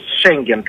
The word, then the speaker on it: Шенген: Според мен Европа доста закъснява с важни решения спрямо България, като например Шенген, както е правилното ударение, да. Но българите му казват Шенген.